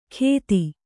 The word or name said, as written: ಗೆದಿ